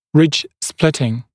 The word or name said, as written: ridge splitting